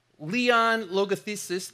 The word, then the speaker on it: Leon Logothetis: Leon Logothetis, that's how you say his name.